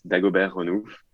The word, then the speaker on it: Dagobert Renouf: Am I pronouncing that really, really badly for an Australian, or is that okay? – It's okay. Okay. You're not far. Like, it's Dagobert Renouf.